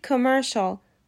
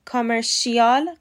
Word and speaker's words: commercial: تلفظ صحیح کامرشال کامرشیال غلطه. کلا هر جا آخر لغتی C I A L دیدین شال تلفظ میشه و شیال نداریم. مثلا financial, official, special که همگی به C-I-A-L ختم میشن.